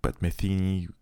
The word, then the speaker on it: Pat Metheny: C'est l'album qui que Pat Metheny Pat Metheny, comme on prononce ça américaine, et John Scofield cite comme euh, la référence.